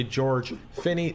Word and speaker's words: George Finney: Joining me is the one, the only George Finney, the CSO for SMU, Southern Methodist University.